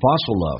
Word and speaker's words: bustle: Hustle L 跟 N 形成个连叫 Hustling In a Hustling Bustle of Bustle 跟 of 有一个连叫 Bustle of 记住那个 T 都是不发音的这句话就变成慢速版本 In a Hustling Bustle of Modern Day Life People seldom stop to enjoy the beauty of nature Seldom 很少 Stop to 要理解 alright?